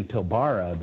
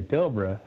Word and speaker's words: Pilbara: saying Pilbara, but it, you know, instead of Pilbara, you got to say Pilbara. – really? – So, okay. Yeah, well, I've been saying oh, Pilbara.